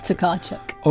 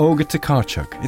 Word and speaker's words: Tokarczuk: Olga Tokarczuk. – Olga Tokarczuk.